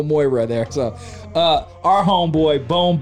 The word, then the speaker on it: Moira: And now, I'm not of a gamer, so make sure I'm pronouncing this right. Moira in the house? Yeah. Am I right on that? – That is correct.